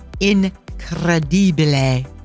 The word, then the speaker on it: incredibile: The word incredible in Italian is incredibile.